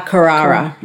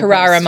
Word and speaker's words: Carrara: I will be doing it at, is it Carrara Markets? Is that how you say it? – Carrara on the Gold Coast. – Carrara Markets.